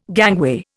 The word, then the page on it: gangway: Indian